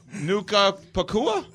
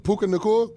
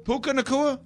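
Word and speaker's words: Puka Nacua: Nuka Pacua, right? Is that not right? – No. – What is it? Well, you can tell me what it is? – How do you say it? Puka Nakua? – Puka Nakua? – I think that's how you pronounce it. – Yeah. I got his first and last name. I got it backwards there.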